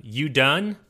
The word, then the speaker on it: Udûn: It's called Udûn, I believe is how you pronounce it.